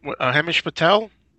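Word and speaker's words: Himesh Patel: Hemish Patel.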